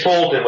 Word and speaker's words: Fulton: Is it Bolton? – Well, it's Fulton with an F, F-U-L-T-O-N. – Well, F, Fulton.